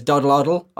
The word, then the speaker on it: Doddleoddle: Dodie is also known as Doddleoddle on YouTube. I hope I said that right. Doddleoddle. – Right. That's right.